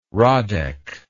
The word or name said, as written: radek